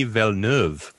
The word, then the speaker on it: Villeneuve: And then I looked it up in the internet, blah, blah, on the internet, and it's just Denis Villeneuve.